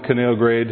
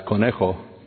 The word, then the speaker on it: Conejo: This This is, I always love coming down the Canal Grade, and my wife always asks me to remind her, how do you pronounce that here? Because, of course, we speak Spanish, and it's Conejo. But here in Oxnard, we say Canal Grade.